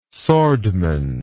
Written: swordman

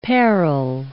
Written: parrel

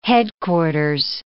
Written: headquarters

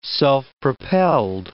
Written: self-propelled

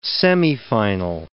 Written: semifinal

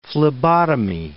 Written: phlebotomy

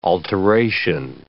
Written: alteration

